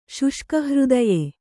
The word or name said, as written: ಶುಷ್ಕಹೃದಯ